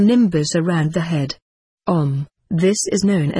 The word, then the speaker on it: Om: The pronunciation is Om.